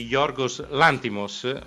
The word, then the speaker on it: Yorgos Lanthimos: Ora usiamo gli ultimi minuti di trasmissione per parlare del film L'Aragosta, The Lobster, di Yorgos Lanthimos. Abbiamo scoperto che si deve pronunciare così.